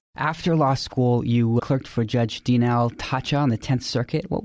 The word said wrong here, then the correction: Tacha: Yeah, forgive me for correcting the pronunciation, but she pronounces it Taha. – My mistake. – And I was very fortunate to clerk for Judge Taha.